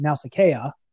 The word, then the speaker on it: Nausicaä: The name Nausicaä, which you notice has a diéresis over the second A, which means that the name should be pronounced Nausicaä, but is not, of course, honored. That is what that diéresis means. That is a diacritical mark that means that the vowel sounds are separate.